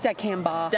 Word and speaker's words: dicamba: Is that, did I say it wrong? – It's dicamba.